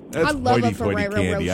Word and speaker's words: Ferrero Rocher: And nice I said Ferrero Rocher. I know that's not how you say it, but don't tell me the correct way. – Ferrero Rocher. – Isn't it Rocher? Ferrero Rocher. Ferrero Rocher. – I thought you said it right.